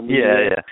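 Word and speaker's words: Mudiay: But there's a couple, like you alluded to earlier, there's a couple of really interesting point guard prospects in D'Angelo Russell and Moutier. Am I saying that right? Emmanuel Moutier.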